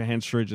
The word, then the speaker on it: Henstridge: Henstridge.